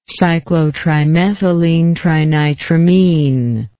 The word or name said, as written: cyclotrimethylenetrinitramine